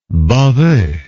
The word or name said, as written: baveux